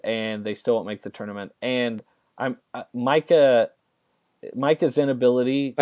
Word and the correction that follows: Mika: I'm pretty sure it's still pronounced Mika, but that's fine. – Gosh. Mika.